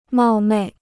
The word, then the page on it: 冒昧: mào mèi